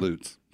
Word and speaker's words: Lutz: But there's no other vowel to make it a long U. – It's Lutz.